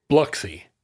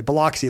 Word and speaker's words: Biloxi: Because locals say Biloxi with Biloxi. Like a schwa, Whereas outsiders will say Biloxi, like it's like bagels and lox.